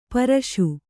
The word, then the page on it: ಪರ್ಷ: parṣa